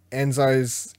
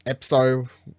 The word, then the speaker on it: Epzo: Enzo's Epzo.